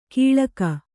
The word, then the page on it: ಕೀಳಕ: kīḷaka